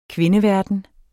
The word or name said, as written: kvindeverden